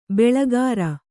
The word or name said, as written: ಬೊಳ್ಳುಗರೆ